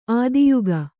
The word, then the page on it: ಆದಿಯುಗ: ādiyuga